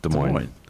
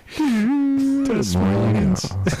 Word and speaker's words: Des Moines: Des Moines. – All right. – The S is silent. – I didn't know that. – Now you know. – Now I know. The more you know. – Des Moines.